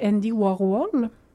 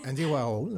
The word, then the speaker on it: Andy Warhol: Et à un moment donné, il y a quelqu'un qui lui a présenté Andy Warhol. – Oui. – Je le prononce pas bien. – Mais... Andy Warhol. – Oui, merci.